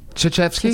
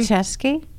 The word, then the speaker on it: Krzyzewski: A lot of people want to pronounce it as Krzyzewski. – They want to, but it ain't right. – But it's Coach Mike Krzyzewski. – Coach freaking K. – How do you pronounce it? – Krzyzewski. – Krzyzewski. – Krzyzewski.